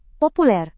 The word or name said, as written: populer